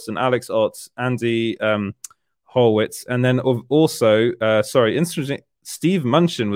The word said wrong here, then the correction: Mnuchin: was also, I can't pronounce his name, Munchen. So the guy that was Donald Trump's right-hand man, I think. – Mnuchin, there we go.